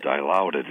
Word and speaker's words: Dilaudid: Man, Dilaudid is amazing.